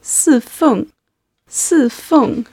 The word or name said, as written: sifeng